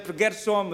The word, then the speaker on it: Gershom: Sometimes there is problem for Romanian to pronounce my name Som like a sleeping, but I say myself Gershom, reminding myself a traveler.